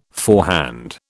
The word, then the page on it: forehand: British English